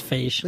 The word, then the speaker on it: Feige: Here's what Kevin Feige said. – How do you spell that? – F-E-I-G. – I think it's Feige, like beige.